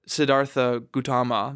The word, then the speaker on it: Siddhartha Gautama: Siddhartha Gautama, this, you know, the guy.